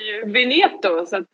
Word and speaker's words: Veneto: Vi, va, prosecco, då ska vi till Veneto va? Eller vad ska vi då? – Ja precis, gud vad bra ditt uttal har blivit nu.